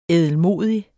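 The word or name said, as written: ÆDELMODIG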